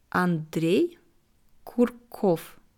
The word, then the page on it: Andrej Kurkow: slow